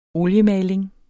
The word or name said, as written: oliemaling